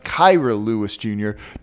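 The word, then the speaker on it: Kira Lewis Jr.: Kyra Lewis Jr., and it is pronounced Kyra Lewis Jr., does all of that.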